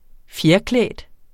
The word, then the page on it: fjerklædt: -ˌklεˀd